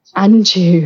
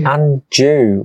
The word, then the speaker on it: Anjou: She was engaged for a time to the Duke of Anjou. – The Duke of Andrew? What? – Anjou. – Anjou.